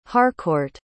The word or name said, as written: harcourt